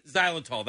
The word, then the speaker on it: xylitol: I mispronounced it. He mispronounced it. – Xylitol. That's it.